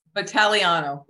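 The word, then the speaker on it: Vitagliano: Vitaliano. – Vitaliano. Okay. Yeah, that's okay. I want to pronounce the G. – I know. The G is an alphabetical waste and it messes everybody up.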